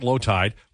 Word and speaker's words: Lowetide: Also, Twitter at Lowtide with an E, L-O-W-E-T-I-D-E, and at Declan Kruger.